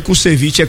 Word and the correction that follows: Krushevski: é Krushevski.